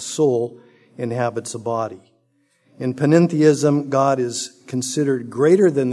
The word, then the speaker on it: panentheism: One is called panentheism. No, I did not mispronounce pantheism. This is a separate worldview, panentheism.